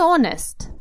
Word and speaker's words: honest: Прекрасное выражение, что значит Честно говоря, обратите внимание, слово honest, который значит честный, давайте с ним сначала разберемся немножечко, пишется с буковкой H, а если вы подпишетесь на мой подкаст Do You Speak English Podcast, я обязательно этот текст выложу, вы можете посмотреть, как оно пишется. Но произносится без буковки H, honest, потому что слово англичане взяли из французского.